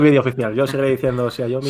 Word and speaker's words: Xiaomi: cómo lo dicen ellos? – a ver, venga. Ellos dicen, según el vídeo que pusieron, era Xiaomi.